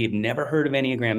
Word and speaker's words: Enneagram: So Enneagram, spelled E N-N-E-A-G-R-A-M, pronounced Enneagram, like A-N-Y-G-R-A-M.